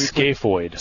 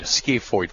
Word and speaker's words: scaphoid: Scaphoid. – Scaphoid.